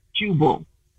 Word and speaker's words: Jubal: Jubal. – Yes. – How do you spell that? – J-U-B-A-L.